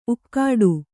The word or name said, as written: ಊಗಾಡು